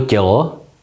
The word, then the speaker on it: tělo: Tedy slovo tělo nehláskujeme jako T, E s háčkem, L, O, nýbrž jako T, E, L, O.